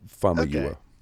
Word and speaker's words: Famuyiwa: That's how I've heard it pronounced, as Famuyiwa.